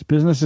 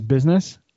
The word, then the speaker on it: business: Business is actually, even though we say it as business, it's actually three syllables. It's business. Yes, it is.